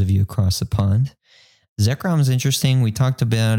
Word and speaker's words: Zekrom: I have always known it as Zekrom, so I do not have any controversial pronunciations to give the community this week for Lashback.